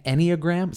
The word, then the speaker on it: Enneagram: And and this that I'm talking about is an Enneagram. Is that how you say it? – Yes. – Enneagram.